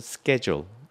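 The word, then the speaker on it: schedule: 루즈벨트한테 니네는 천박하게 쉐줄이지 무슨 스케줄이니 SCH 발음이잖아 쉐 이렇게 읽어야 될 거 아니야. 그렇게 하니까 루즈벨트가 받아쳤어요. 그래 그럼 니네도 스쿨이라고 하지 말고 슐이라고 읽어. 그럼 나도 그렇게 읽어줄게. 여하튼 말로 먹고 사는 사람들이라 말 잘하죠. 그래서 스케줄, 쉐줄 다 돼요.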